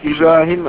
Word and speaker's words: Ibrahim: My name is Ibrahim Adıyaman. Let me spell it. – I-B-R-A-H-I-M-M, like maple.